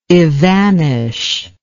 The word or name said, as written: evanishment